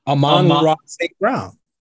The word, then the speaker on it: Amon-Ra St. Brown: Amon-Ra St. Brown. – You said Ahmad.